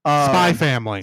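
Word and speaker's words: Spy × Family: Spy Family. – Spy Family. – The X is silent. – Oh, okay. – It is there for stylistic reasons alone.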